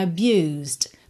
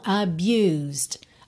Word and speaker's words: abused: Abused. Porque a gente tem o S direto pro D. Esse E de abused não é pronunciado. Você não diz abused, tá? Então, abused, abused.